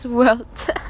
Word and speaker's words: Huerta: A quote that Dolores Huerta... – So how do you say it? – Dolores Huerta.